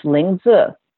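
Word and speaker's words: Lingzhi: And in the pinyin, which is it's L-I-N-G-Z-H-I, pronounced Lingzi. That's the Mandarin version of the herb.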